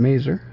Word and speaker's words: Mazar: This was from Mazar, or maybe it's pronounced Mazer sometimes, names in Israel are pronounced like that.